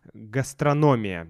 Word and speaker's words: гастрономия: Э, кстати, гастрономия по-испански гастрономия, а по-русски гастрономия. Где будет ударение? Давайте проверим. Да, гастрономия, действительно. Я уже редко использую это слово.